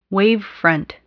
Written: wavefront

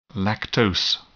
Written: lactose

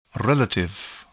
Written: relative

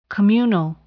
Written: communal

